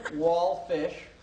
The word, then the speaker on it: Walfish: Howie Walfish. – Yes. – W-A-L-F is in Frank, I-S-H.